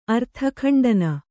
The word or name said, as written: ಅರ್ಥಖಂಡನ